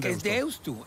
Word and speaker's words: Deusto: ¿Qué haces con Deusto? – Es Deusto, porque se escribe con U final.